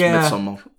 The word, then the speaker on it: Midsommar: It's Swedish, so it's Midsommar. – Azzy, I think, the guy who directed it calls it Midsommar.